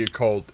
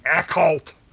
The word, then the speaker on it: occult: We've been pronouncing it wrong for all of our lives. It is not the occult. It's the occult.